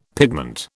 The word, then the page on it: pigment: British English